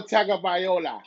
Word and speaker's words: Tagovailoa: Tagovailoa. If you don't know how to say his name, ask me. I'll say it for you. Tua Tagovailoa.